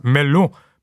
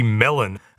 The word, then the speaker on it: Melun: Within 3 days, he had left Paris and retreated to a place that you assume in French is pronounced Melun, but in Australian English would be Melon, about 40 kilometers southeast of the capital.